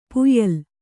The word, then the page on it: ಪಾಯಿಲಿ: pāyili